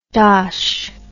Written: dosh

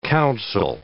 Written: council

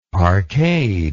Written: parkade

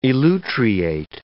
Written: elutriate

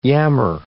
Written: yammer

